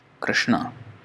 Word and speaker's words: Krishna: That in English we write as N with a dot below. As you can see, Krishna, na, that na, this na is what we are pronouncing here, Krishna.